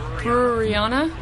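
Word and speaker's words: breweriana: I can never pronounce the word. You got to help a brother out. Can you say that word? – Brewerianna? – Brewerianna.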